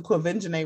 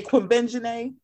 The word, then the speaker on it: Quvenzhané: But you say can't say Quimbenjane.